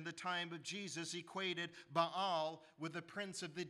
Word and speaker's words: Baal: Most people say Baal, but it should be pronounced Baal, the Canaanite deity.